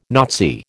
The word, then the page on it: Nazi: American English